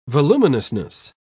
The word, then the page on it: voluminousness: British English